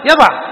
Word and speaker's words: يضع: طيب نحن الآن ننطقها كيف؟ يضع. قالوا لأن آخر وضع حرف إيش؟ حرف حلق، حرف الحلق يناسبه الكسر أو الفتح، حرف الحلق يناسبه الفتح.